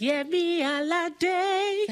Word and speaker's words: Alade: I want you to say it how you say it in the songs. It's Yemi Alade.